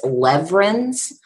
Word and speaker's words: Lewerenz: Well, actually, the W is pronounced a V. Now, why it's not actually a V, I'm not sure. So it's Lewerenz.